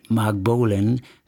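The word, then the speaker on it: Marc Bolan: Mark Bolan wie Mark Bolan, der Sänger von der Gruppe T-Rex. Der schreibt sich zwar anders, spricht sich aber gleich aus.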